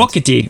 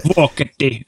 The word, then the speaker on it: Vuokatti: So the news originally from World Juniors U23s, and I'm going to let you pronounce the town in Finland. – Vokati. – Okay. I would have mispronounced it. – Vokati.